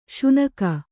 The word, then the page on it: ಸೀನ್ಕೆ: sīnke